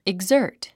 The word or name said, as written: exert